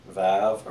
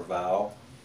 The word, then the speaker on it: Vav: The next one is vav or vow.